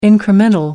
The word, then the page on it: incremental: American English